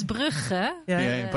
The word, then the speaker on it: Brugge: Bruges. Yeah. It's Bruges, isn't it? Brugge. – How do you say it in a Dutch way? – So in Dutch, it's Brugge.